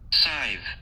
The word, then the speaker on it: scythe: Okay, first of all, look, it says, pronounce it. – Sith. – Huh? Scythe. – Oh, Lord. I can't believe I just botched that.